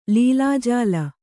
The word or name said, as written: ಲೀಲಾಜಾಲ